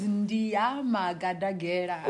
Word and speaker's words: Ndia Madagadela: So my name is Ndia Madagadela.